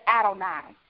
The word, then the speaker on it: Adonai: Some people pronounce it differently. Today I'm pronouncing it as Adonai. A-D-O-N-A-I is another one of the basic names of God in the Old Testament.